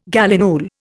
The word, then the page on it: gallinule: Indian